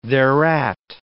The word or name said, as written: thereat